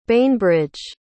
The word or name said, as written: bainbrydge